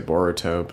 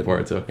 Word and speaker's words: Boruto: I mean, I usually just say Boruto, but yeah, Boruto. I guess if I were in Japan, yeah, I would pronounce it differently.